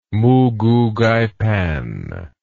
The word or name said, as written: moogoogaipan